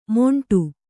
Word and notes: ಮೊಂಟು: kannada

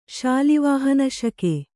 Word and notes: ಶಾಲಿವಾಹನಶಕ: śali vāhana śaka

